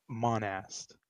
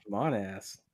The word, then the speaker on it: Monast: Well, the Blue Beam Project is a conspiracy theory started by, drumroll please, boring, Serge Monast. – Got him. – I think that's how you say his name. – Serge Monast. – Got him. – Not Monast, Monast.